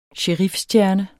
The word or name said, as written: sherifstjerne